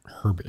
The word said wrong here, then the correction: Herbers: I can't pronounce Katya. Katya Herbers.